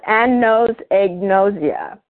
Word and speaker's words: anosognosia: And there's a disease or an affliction, it's called anosognosia.